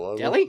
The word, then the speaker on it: Delhi: Spell it again. – D-E-L-H-I. – Delilah? – Delhi? – Delhi? – Like New Delhi?